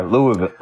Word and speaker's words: Louisville: That's how they say it down there, Louisville.